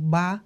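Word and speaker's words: ba (八): Y en los países asiáticos se celebraba antes en agosto, porque leía yo que en chino agosto se pronuncia como ba, y padre también se pronuncia como ba, entonces era como ba, ba.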